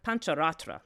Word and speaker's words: Pancharatra: a popular Pancharatara, um, uh, Panchara, Pan- Pancharatra, thank you, I'm saying it wrong, Pan- Pancharatra text, which says that she single handedly undertakes the creation of the universe with only one billionth a part of herself.